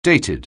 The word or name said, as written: dated